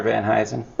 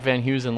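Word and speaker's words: Van Heusen: I think it's Mr. Van Huysen. – Oh, is that how you pronounce it? So that's another thing. We thought it was like Van Huysen, like the shirt.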